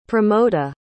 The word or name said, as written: pramoda